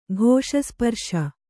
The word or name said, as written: ಘೋಷಸ್ಪರ್ಶ